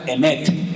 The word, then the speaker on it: emet: La palabra verdad, se la voy a enseñar para que aprendan a decirla en hebreo, se pronuncia emet. Dígalo conmigo, no tenga miedo. Emet, E-M-E-T, Emet.